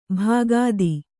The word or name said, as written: ಭಕ್ತಿ